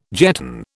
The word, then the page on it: jetton: British English